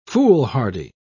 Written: foolhardy

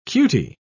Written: cutie

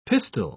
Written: pistil

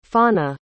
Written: faunae

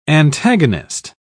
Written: antagonist

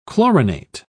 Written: chlorinate

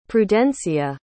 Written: prudencia

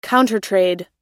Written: countertrade

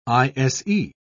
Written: ise

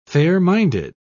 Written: fair-minded

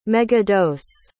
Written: megadose

